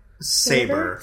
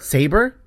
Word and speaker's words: Sabra: Saber. – Saber? Okay. – Like the sword.